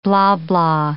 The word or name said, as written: blah-blah